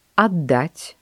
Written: отдать

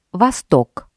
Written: восток

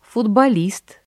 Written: футболист

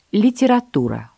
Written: литература

